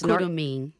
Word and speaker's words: Curumim: It's Curumim.